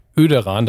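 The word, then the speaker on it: Oederan: Liebe Kühe, wenn schon das Kleinerzgebirge in das, das müsst ihr direkt gucken, Öderan, das, das OE wird im Übrigen als Ö ausgesprochen. Ich habe schnell nachgeguckt, was er da noch geschrieben hat.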